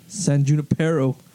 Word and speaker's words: San Junipero: San Juniper, you guys are saying that wrong. – Is it? – How do you say it? – San Junipero.